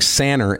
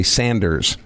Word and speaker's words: Sanner: But it's actually pronounced Levan, and I can relate to that, because my last name, of course, being Sanner, S-A-N-N-E-R, everybody wanted to call me Sanders.